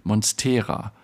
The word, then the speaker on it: Monstera: Er sagt, am häufigsten sei ihm jetzt untergekommen die Aussprache Monstera. Also mit einem betonten E. Aber hast du es dann nicht beim allerersten Mal eigentlich sogar richtig gesagt? Ich habe dann Monstera gesagt.